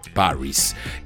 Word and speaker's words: Pvris: Se llaman Paris, aunque para evitar conflictos con su nombre utilizan una V en lugar de la A, así se escribe Puris, pero la pronunciación correcta es Paris.